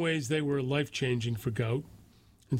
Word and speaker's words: Gout: In 2005, Bonna, B-O-N-A, and Monica Gout, pronounced Gout. It's Gout, spelled G-O-U-T, is pronounced G-W-O-T, Gout, sought to leave war-ravaged South Sudan.